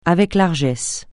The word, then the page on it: avec largesse: francais